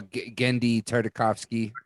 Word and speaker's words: Genndy Tartakovsky: You know, the only person that I could think of that does that, at least in the modern era, is how do you pronounce his name? Genndy Tartakovsky. Is that how? – Genndy Tartakovsky. Yeah.